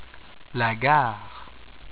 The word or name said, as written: La gare